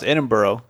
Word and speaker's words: Edinburgh: You know, it's actually pronounced Edinburgh.